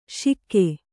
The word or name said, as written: ಶಿಕ್ಕೆ